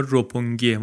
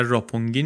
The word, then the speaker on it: روپونگی: تلفظ درستش هم انگار روپونگیه، ما یه خورده بیشتر راپونگی نوشتیم، روپونگی انگار در ژاپنی درست‌تره.